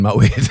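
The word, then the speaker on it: Moët: When it's like you actually realise that Moet is the correct way to say it, like poet.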